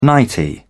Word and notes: nightie: British English

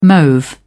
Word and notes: mauve: British English

